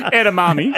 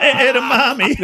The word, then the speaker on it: edamame: Edamame. – Edamame.